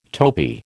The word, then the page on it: topi: American English